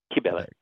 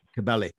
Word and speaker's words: Kybele: Ceremonies from Eleusis, and I know that you're pronouncing it, is it Kybele? – Kybele. – Kybele, yes. So did they preserve both of them? – We don't know.